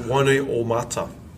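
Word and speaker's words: Wainuiomata: My hometown is called Wanui Omata.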